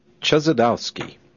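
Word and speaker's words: Chossudovsky: And finishing up on the Michael Chos, it's spelled Chosudovski. Chuzadowski is his name, Global Research.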